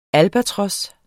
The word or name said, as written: albatros